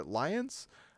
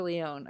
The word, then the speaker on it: Lyonne: I don't know her actual pronunciation of her name, but she's a rad actress. She's the one from, like, Russian Doll. – Oh, I think it's Natasha Lyonne. I think that makes more sense.